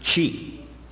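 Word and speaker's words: te: The second one is chi. Like cheese, the beginning of cheese.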